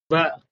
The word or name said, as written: v